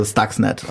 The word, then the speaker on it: Stuxnet: Also Stuxnet auf Englisch ausgesprochen.